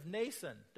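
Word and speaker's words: Mnason: Some of the disciples from Caesarea accompanied us and brought us to the home of Nason, don't pronounce the M, where we were to stay.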